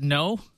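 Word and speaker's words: Kno: Is it No or Kano? – I think it's No. It's K-N-O. – I think it's No. – So the company's, it's spelled K-N-O. I guess it's a play on knowledge, that word.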